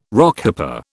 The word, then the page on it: Rockhopper: British English